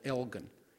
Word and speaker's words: Elgin: And then the second Sabbath in December, I'm supposed to be preaching in a town in Texas that should be pronounced Elgin, like Elgin, Illinois, but they call it Elgin.